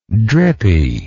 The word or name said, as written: drippiness